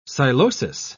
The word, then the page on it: psilosis: British English